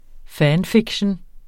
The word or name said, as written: fanfiction